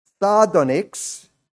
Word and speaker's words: sardonyx: Sardonyx, that's how you pronounce that, verse 20, that is a layered stone.